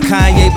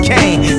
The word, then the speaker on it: Kanye: Now all I need is y'all to pronounce my name. It's Kanye, but some of my plastics still say Kane.